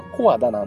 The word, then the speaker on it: 小和田: も、あの、静岡にはあの、ここ本当に区かよっていうところがいっぱいありますんでまあこの小和田駅もそんな場所になってくるんですけども、えっ、と、まあこれが、この駅がですね、えー、発音は小和田なんですけども、小さいに平和の輪に田んぼの田と書くんですね。